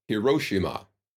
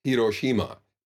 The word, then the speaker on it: Hiroshima: A lot of Americans will say Hiroshima when it's Hiroshima. Each of these syllables should be pronounced.